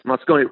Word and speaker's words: Moscone: I think I'm pronouncing his name properly there. – Yes, Moscone. Well, Moscone was sworn in 76 as mayor.